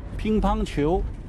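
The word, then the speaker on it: Pingpongqiu (乒乓球): Pingpongqiu. – Pingpongqiu, zeg ik dat goed? – Yes, oké. Okay.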